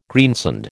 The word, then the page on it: greensand: American English